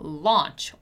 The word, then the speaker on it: launch: When we have the letters A and U together, we're normally going to pronounce it as ah, right? So launch or Australia or author or audio and so on, right?